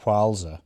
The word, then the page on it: Whalsay: Shaetlan